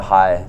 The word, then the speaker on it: Hai: Yeah, so we actually call that the Hai. It's the H-A-I, is how it's spelled.